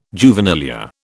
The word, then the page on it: juvenilia: British English